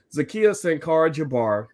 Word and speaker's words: Zakiyah Sankara Jabbar: And, y'all saw Zakiyah Sankara Jabbar. Did I say that right? – Yeah, you got me.